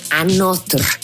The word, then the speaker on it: Anotr: Se llaman Another, pero no se escribe como tal, se escribe A-N-O-T-R, Another.